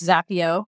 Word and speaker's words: Zapier: It's Zapier. – Z-A-P-I-E-R. To rhyme with happier. Or happier.